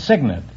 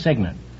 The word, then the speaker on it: cygnet: No, it's Signet. It's spelled C-Y-G-N-E-T, but it's pronounced Signet.